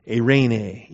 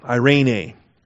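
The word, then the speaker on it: Irene (εἰρήνη): The accent's on the first eta there, so it's Irene, E-I-R-E-N-E. Epsilon iota rho, eta nu eta, E-I-R-E-N-E. Accent on the middle syllable, Irene.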